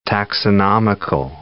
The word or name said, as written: taxonomical